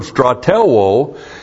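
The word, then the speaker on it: Stratelwo (στρατεύω): And when you have it with the verb that we have the verb stratelwo.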